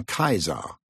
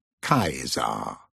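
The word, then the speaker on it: Caesar: The boys of the lower school call him Kaiser, pronouncing the C as a K, like the Latin teacher taught them. Kaiser.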